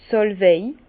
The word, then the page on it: d' Sàlwei: Bas Rhin; Herrlisheim